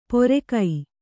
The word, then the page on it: ಪೊರೆಕೈ: pore kai